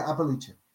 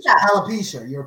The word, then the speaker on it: alopecia: Yeah, Appalachia. – You know one of the cast members. – Alopecia. – Alopecia. – Yes. One of the cast members of the Law Redemption has alopecia, mm-hmm.